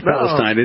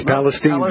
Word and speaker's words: Palestine: Yep, you can't say it like we think the Old Testament says Palestine. It's Palestine, baby.